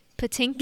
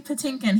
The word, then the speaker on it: Patinkin: Pit- Mandy Patinkin. Yes, Mandy Patinkin.